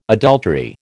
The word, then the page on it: adultery: American English